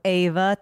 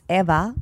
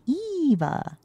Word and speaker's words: Eva: But people go Ava thinking they're saying my name in Spanish, and they're not. It's because it's Eva or Eva, but it's never Ava. – It's never Ava. – It's never with an A. So I just go, no, it's with an E.